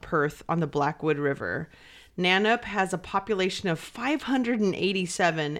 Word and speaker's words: Nannup: At the end of 2003, Simon, Chantel, Leela, and Tony moved into a farmhouse in the remote town of Nanup. And I know I've said that right because I've heard that town name a lot. And it's spelled N-A-N-N-U-P. It's not that hard to say.